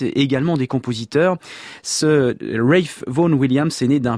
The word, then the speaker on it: Ralph: Je dis bien Ralph puisque à plusieurs reprises j'avais prononcé ce prénom à la française, Ralph, et bien des auditeurs m'ont signalé que la exacte prononciation, effectivement, c'est cela. C'est bien Ralph Vaughan Williams,